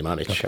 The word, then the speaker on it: Mamić: I think as well. I don't know his first name, but Mamić, definitely Mamić.